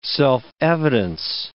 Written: self-evidence

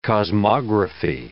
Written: cosmography

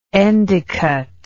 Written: endecott

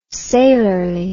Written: sailorly

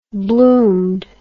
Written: bloomed

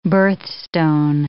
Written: birthstone

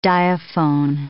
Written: diaphone

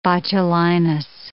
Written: botulinus

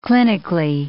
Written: clinically

